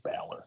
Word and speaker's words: Balor: I just try to pronounce names how the people who have the name say it, and I think Finn just says Balor.